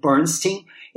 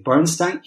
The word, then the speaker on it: Bernstein: E tanto que, para diferenciar no mundo musical, o Elmer que, o, cujo nome a gente pronuncia Bernstein, ele era conhecido como Bernstein do Oeste, por causa de Hollywood. E o Leonard Bernstein, pronuncia-se, no caso dele, Bernstein, era conhecido como o Bernstein do Leste.